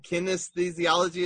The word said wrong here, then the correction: kinesiology: is that right? – Kinesiology. – I wasn't even close.